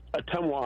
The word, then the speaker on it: Ottumwa: It's pronounced Ottumwa, Iowa. Hey, Ottumwa.